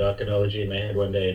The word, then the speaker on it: Archonology: What I was hoping to specifically address is your, now, how do I say it? Archonology? How do you say it? – Archonology. – Archonology. – Like Chardonnay, the H is silent.